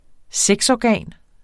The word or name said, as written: sexorgan